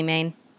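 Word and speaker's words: Maines: Is that right, Whitney? Natalie Maine? – Yeah. – Okay, that's how you pronounce it.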